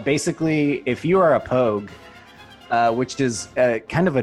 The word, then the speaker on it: POG: What is it's a POG pronounced pogue? – Pogue.